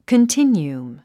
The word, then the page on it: continuum: kəntínjuəm; コンティニュウム